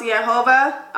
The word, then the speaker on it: Jehovah: So Jehovah is pronounced Yehovah, obviously.